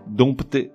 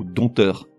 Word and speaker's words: dompter: Là, j'avoue, le mot est piégeux puisqu'il s'écrit avec un P devant le T. D-O-M-P-T-E-R. Et pourtant ce P ne se prononce pas, il est muet. On dit donc dompté ou dompteur.